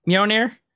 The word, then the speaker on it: Mjolnir: Mjolnir? – Yes.